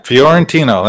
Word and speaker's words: Fiorentino: Yeah, Fiorentino.